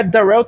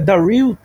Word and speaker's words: Darrell: Ah, inclusive, vou ajudar aqui, eu descobri que o nome dele não é Darrell Taylor, se pronuncia Daryl Taylor.